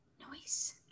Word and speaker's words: Neuse: So big weekend for Sheldon Noisy, whose last name does not look like it would be said Noisy. N-E-U-S-E. – Quietly. Noise. You should say his name. Whisper it. – Noise. – That's better. Excellent.